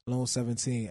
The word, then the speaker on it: Lone17: Lone 17, LONE17.